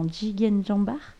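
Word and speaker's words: Jigeen Jambaar: Tu fais partie euh, d'un, projet euh, Jigen, alors je le prononce mal certainement, Jigen Jambar. Non, c'est, ça, c'est ça.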